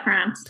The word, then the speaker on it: Franc: Yeah, Franc.